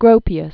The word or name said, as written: Gropius, Walter Adolph